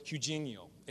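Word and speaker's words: hygiaino: Also, if you study that word, sound, it's in the Greek, it's this word, hygienio. It sounds like the word hygiene, right?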